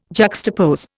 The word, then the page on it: juxtapose: Indian